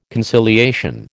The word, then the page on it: conciliation: American English